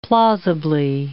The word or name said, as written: plausibly